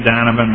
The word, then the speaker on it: Donovan: My name is Tim Donovan. My last name is spelled D-O-N-O-V-A-N.